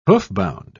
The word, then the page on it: hoofbound: British English